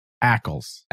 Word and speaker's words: Ackles: Repeat after me. Ackles. – Ackles.